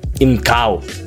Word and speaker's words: Инкал: Наконец-то Камильфо анонсировали Инкл. Инкал. Третьего ударения здесь нет.